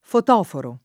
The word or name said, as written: fotoforo